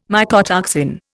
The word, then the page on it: mycotoxin: Indian